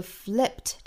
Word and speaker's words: flipped: 好，那我们注意这个尾音的话呢，应该读成一个清辅音 t，而不是 d，并不是写的是 ed 就读成 d 的音啊，flipped。